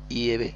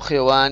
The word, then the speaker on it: Yehvé: El hebreo antiguo, que es bastante diferente al hebreo moderno, pues estudiosos en el hebreo arcaico, saben ellos, han descubierto y que la pronunciación del Dios de Israel más correcta es Yehvé o Yehvé, Yehvé, y no Jehová ni Yahvé, ni Yahvé, es Yehvé, parece la pronunciación más correcta.